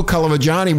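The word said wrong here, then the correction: Colagiovanni: will have, I said his name right. – No, you didn't. Yes, I did. No. – What is it? – Cole Giovanni.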